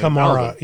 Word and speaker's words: Kamara: So they could spare the guy to put on Kamara, or Kamara, or however you say his name. – Kamara. Alvin. Yeah.